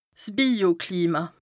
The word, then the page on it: 's Bioklima: Bas-Rhin